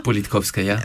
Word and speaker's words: Politkovskaya: Et je pense à cette femme, cette très grande journaliste, dont j'ai du mal à prononcer le nom correctement, Anna Politkovskaya. – Politkovskaya, oui.